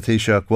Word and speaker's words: Taoiseach: Now, the GA will listen to the Toasters of Ireland, His Excellency Micheál Martin. – Okay, some pronunciation problems, but this is how the Taoiseach was introduced to the UN.